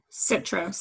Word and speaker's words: Citro: Citro, C-I-T-R-O.